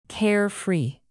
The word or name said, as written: carefree